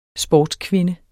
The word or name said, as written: sportskvinde